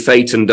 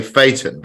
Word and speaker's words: Phaeton: How you say it defines whether you live in the home counties or not. Because we say Phaeton, but they say Phaeton. – Phaeton.